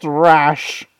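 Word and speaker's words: Ra's: Yeah, do your best Raz impression. – It's pronounced Rash.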